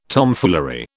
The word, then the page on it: Tomfoolery: British English